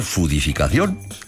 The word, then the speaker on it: foodificación: Puedo escribir foodificación y pronunciar fudificación.